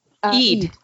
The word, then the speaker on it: Eid: I think it's Eid, is how to pronounce it? – Eid. – Okay, Eid.